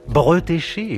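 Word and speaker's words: Bretécher: Bretéché, et oui, on prononce comme ça,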